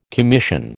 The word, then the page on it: commission: American English